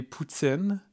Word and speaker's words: poutine: You call it poutine there, not poutine. Am I saying it wrong? – No, you're not saying it wrong. I guess you're just saying it in an English way. But okay. Since it's a French or a Quebecois dish, it would be poutine.